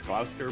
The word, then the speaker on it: Gloucester: I think it's Gloucester, pronounced Gloucester, Virginia.